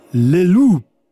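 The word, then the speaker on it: Le Loup: Lelou. Ich nehme an, das P spricht sich nicht am Ende. Es ist stumm. Lelou, der Wolf.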